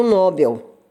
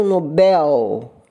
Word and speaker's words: Nobel: Aqui eu faço um parênteses porque eu ouço muita gente pronunciar Prêmio Nobel, quando na realidade podem confiar nesta velha mestra de língua portuguesa, o correto é dizer Prêmio Nobel a palavra é oxítona. A sílaba mais forte é a última.